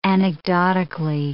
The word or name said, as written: anecdotically